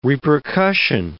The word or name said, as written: repercussion